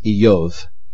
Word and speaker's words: Iyov: His name, it is believed, if it comes from Hebrew, and in fact in Hebrew it would be pronounced Iyov.